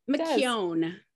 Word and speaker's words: McCown: McCown. Yeah, like ice cream cone.